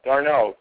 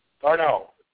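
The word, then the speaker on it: d'Arnaud: I'm going to go with the catcher from your New York Mets, Travis Darnot. – Get the name right. Darnot.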